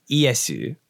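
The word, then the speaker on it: Iesu (イエス): So it's where Jesus's name in Japanese is pronounced Iesu, which is because the Portuguese were the first to bring Christianity to Japan, right? – Mm-hmm.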